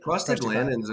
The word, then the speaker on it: prostaglandins: So you had the histamines, the prostaglandins, is that how you pronounce it? – Prostaglandins are interesting.